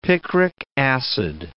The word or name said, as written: picric acid